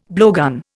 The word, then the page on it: blowgun: Indian